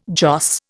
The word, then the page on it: joss: Indian